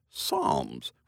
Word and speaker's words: Psalms: Well, I was reading the Book of Psalms the other day, and I noticed it starts with the letter P, but it's pronounced Psalms, which sounds like it should start with an S.